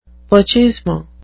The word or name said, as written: pochismo